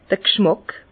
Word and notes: d'r G'schmàck: Bas-Rhin; Herrlisheim

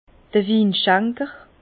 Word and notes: d'r Winschenker: Bas Rhin; Herrlisheim

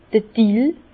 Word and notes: d'r Dill: Bas-Rhin; Herrlisheim

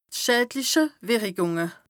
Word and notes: d' schädliche Wìrrikùng: Bas Rhin

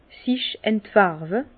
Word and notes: sich entfärwe: Bas-Rhin; Herrlisheim

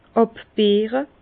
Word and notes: àbbeere: Bas Rhin; Herrlisheim

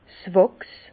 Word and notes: 's Wàchs: Bas Rhin; Herrlisheim

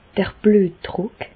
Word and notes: d'r Blüetdrùck: Bas-Rhin; Reichshoffen

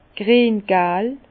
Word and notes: griengal: Bas-Rhin; Herrlisheim

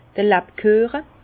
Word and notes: d'r Labküeche: Alsatian; Bas-Rhin; Herrlisheim

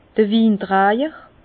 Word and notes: d'r Wintrajer: Bas Rhin; Herrlisheim

